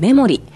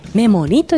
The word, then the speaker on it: 森: 園内にある名水会館アトレトドの中のレストランメモリ、金編に名水の名と書いて森、これでメモリというふうに読むんですが、まあ、素晴らしい森でいいメモリー、いい思い出を作ってねという思いが込められているんだそうですね。